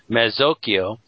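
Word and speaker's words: mazzocchio: The drawing by the Renaissance artist Paolo Uccello is of a Mezzocchio Oh, sorry, she gives me a pronunciation. Mezzocchio, a circular band that would have been worn inside a man's hat.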